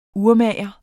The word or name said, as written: urmager